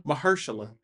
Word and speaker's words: Mahershala: Mahershala.